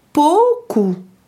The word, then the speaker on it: pouco: Par exemple, ce qui s'écrit ou, au u en portugais, se prononce comme le ao français. Du coup, pouco, qui signifie peu de, se prononce pouco ».